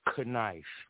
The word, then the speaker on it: knife: So we have words like knife or knife, but the K is silent. Why?